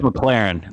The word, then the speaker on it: McLaurin: You have a top 10 undisputed, possibly even top five wide receiver talent in McLaurin. And by the way, am I pronouncing that right? Is it McLaurin or McLaren? – I think it's McLaren. It's McLaren.